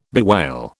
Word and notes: bewail: British English

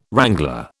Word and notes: wrangler: British English

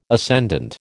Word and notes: ascendant: American English

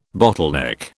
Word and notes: bottleneck: British English

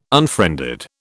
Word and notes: unfriended: British English

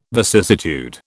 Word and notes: vicissitude: British English

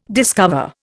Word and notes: discover: Indian

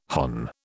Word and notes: hon: British English

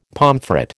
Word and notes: pomfret: American English

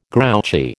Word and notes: grouchy: American English

